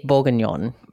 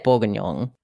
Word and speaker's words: bourguignon: Say beef bourguignon. – Bourguignon? – I say bourguignon.